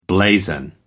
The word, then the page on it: blazon: American English